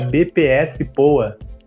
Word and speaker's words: BPS POA: Me explica um pouquinho como é que é, como é que surgiu a ideia da BPS POA. Isso. – É um nome difícil, né? É uma sigla, né? – Eu falei certo? – É, falou assim, é de bazar plus size boa.